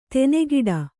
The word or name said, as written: ದನಿಗೊಡು